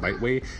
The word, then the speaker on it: Bitewei: So there we go, Biteway, that's how you say it, Biteway, B-I-T-E-W-E-I, new cryptocurrency mining rig that's going to be coming out by a former Bitmain employee.